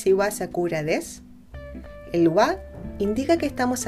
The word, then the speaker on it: desu: Se escribe desu y se pronuncia normalmente des.